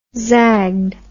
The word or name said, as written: zagged